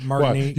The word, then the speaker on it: Martinique: Martinique. Wow. You pronounce it so bad that you got me doubting the pronunciation.